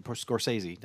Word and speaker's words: Scorsese: Martin Scorsese poured his life and Scorsese. I said it right, didn't I? – Right. Yep.